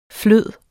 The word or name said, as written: flydende